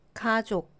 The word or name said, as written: 가족